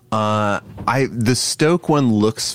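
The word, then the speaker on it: Stōk: We've got, how do you think we pronounce this? Is it Stoke? – Yeah.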